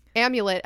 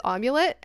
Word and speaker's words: amulet: But this what this girl is referring to in her letter is that Natalia accidentally pronounced the word amulet as omulet, and then it's not somebody an made accident.